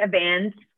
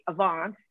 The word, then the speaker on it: Avance: It's Flemish for, if you say it right, Avance, then it sounds like Advance and it's fine.